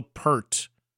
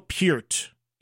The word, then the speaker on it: Peart: And by the way, if you watch that interview I did with him, for clarity purposes, so many people over the decades have mispronounced his name as Neil Peart. It is Neil Peart.